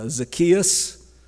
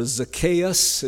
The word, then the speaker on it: Zacchaeus: We have the story of Zacchaeus, or Zacchaeus. It's pronounced different ways.